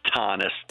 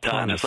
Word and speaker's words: Thoennes: It's Thoennes. Thoennes, like Thomas with an N, Thoennes.